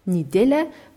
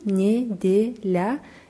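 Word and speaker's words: Nidela (неделя): Ja, Nidela wird mit zwei Je geschrieben, Nidela. Das erste Je wird aber wie I ausgesprochen, weil es unbetont ist.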